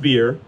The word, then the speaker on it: bier: Some of your translations say bier, or you're looking at it, bier, what is this word? It's pronounced beer.